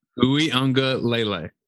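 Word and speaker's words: Uiagalelei: Ui Ungulalele. – Ui Ungulalele. – So then say it quick. Ui Ungulalele. – Ui Ungulalele.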